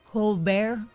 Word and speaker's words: Colbert: Colbert.